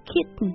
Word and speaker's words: kitten: 好，首先 number one，一般来称呼这个年纪小一点一岁以下的小猫，这种哎呀毛茸茸啊小小一团又小又可爱的叫做这个 kitten。 – 对，在美式发音当中呢，有这么一个小技巧叫做鼻腔爆破，所以这 kitten 它会发成 kitten。